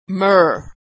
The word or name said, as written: myrrh